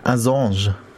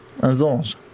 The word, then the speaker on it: Assange: Assange. Es ist Assange. Australia. Es ist Australien. Wie würden Sie Australien aussprechen? Keine Ahnung. Assange. Assange. Keine Ahnung, aber es ist eben französisch ausgesprochen.